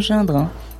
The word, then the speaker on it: Gindre: Gindre, on dit bien Gindre, hein ? – Exactement.